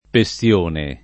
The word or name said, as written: Pessione